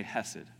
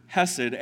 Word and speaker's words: hesed: The Hebrew word for that, I'm probably going to mispronounce it horribly because it involves a throat thing. But I'll just say hesed, okay? It's a very English way of saying it.